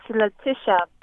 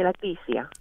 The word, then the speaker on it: Letitia: How do you say it? Letitia? – It's Letitia, but you can call me Leticia.